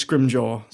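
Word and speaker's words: Scrimgeour: I always read the book and I'm like, Scrimjaw? Like, what is he? – I looked it up. It's Scrimjaw. That's how you meant to say it. – So Scrimjaw, like, immediately got that information and be like, raid that place.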